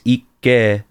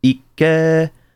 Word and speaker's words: Ikke: And then like, what can I do as like a word that would be like the two K's, Ikke, like in Japanese, when you pronounce it, there's that pause, Ikke, right?